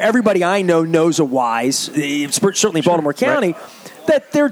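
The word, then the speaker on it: Weis: I called it Weiss because I didn't even, you know, like I didn't even know how to pronounce it.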